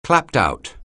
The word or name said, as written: clapped-out